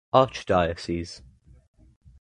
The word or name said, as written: archdiocese